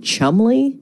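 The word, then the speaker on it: Cholmondeley: So enter stage left today, the Marchioness of Chumley. And I only know how to pronounce that because I asked Alexa. Otherwise, it's like a Jane Austen novel. I can't really understand it. And her name looks like Chalmondeley, which is like the Worcestershire sauce of last names.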